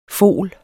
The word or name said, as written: fol